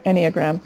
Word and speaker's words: Enneagram: is Enneagrams. Am I saying that right? – Mm-hmm. Singular, Enneagram.